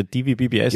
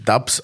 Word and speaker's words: DVBBS: Und ich habe gesagt, ja sicher, DVBBS. – Genau. Und wir ungebildeten Orks haben natürlich geglaubt, dass das so ausgesprochen wird. Wird natürlich Dubs ausgesprochen und haben uns schön blamiert.